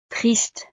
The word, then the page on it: triste: frances